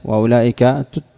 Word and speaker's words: أولئك: نعم والواو في أولى الإشارية وأولئك تكتب ولا تنطق.